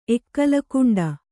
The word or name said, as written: ಎಕ್ಕಲಕುಂಡ